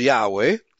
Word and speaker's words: Yahweh: The approximate Hebrew pronunciation is Yahweh, from which the English name Jehovah is derived.